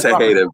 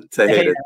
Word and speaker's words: Tejeda: What is it? How do you say it? I say it wrong every time. – It's Tejeda, bro. Tejeda.